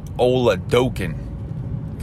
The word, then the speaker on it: Oladokun: I got to get it right. Oladokun. Because I was butchering it on the Steel City Insider podcast the other day.